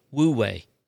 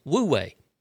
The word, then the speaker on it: Wu Wei: One actually said it's pronounced O-O-O-way, which is Uwei or O-way. But then I went, okay, how do you actually pronounce this? In several places, it's Wu-Wei. It's W-U-W-E-I. It's Chinese. What it is, because it translates to non-action or effortless action.